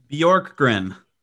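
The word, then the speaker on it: Björkgren: Bjorkgren. – Bjorkgren.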